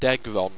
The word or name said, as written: degron